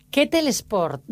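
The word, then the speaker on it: kettlebell sport: a ver si lo digo bien... Ketel Sport, ¿no? Es como se pronuncia, Eva. Correcto. Enhorabuena. – Gracias, correcto. Es así, perfecto.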